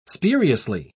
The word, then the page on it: spuriously: British English